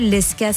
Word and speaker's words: Lezka: Lesca, ça s'écrit L-E-Z-K-A.